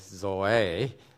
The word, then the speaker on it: Zoe: I'm going to pronounce Zoe as Zoe so that we don't confuse it with Joey, that one of our the Bernard's girls. Zoe is the way the Greeks would pronounce.